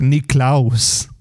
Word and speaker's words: Nicklaus: I thought it was Jack Nicklaus. – Right. – It's Nicklaus.